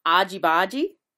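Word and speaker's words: argy-bargy: Argy bargy. So it's really hard on the A and the R.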